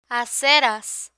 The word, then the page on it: aceras: Spanish